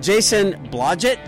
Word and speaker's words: Jason Blodgett: Jason Blodgett, did I say that right? – You got it.